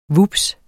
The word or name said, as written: vups